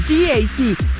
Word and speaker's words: GAC: Se escribe GAC, pero se pronuncia GAC. Repítelo, GAC, que el de al lado te escuche.